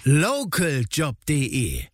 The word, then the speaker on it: Localjob: Ja, aber die Betonung mehr auf Local. – Localjob.de.